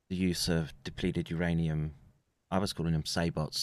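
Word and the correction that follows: sabot: Someone corrected me the T is silent. Sabos, apparently.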